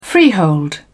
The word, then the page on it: freehold: British English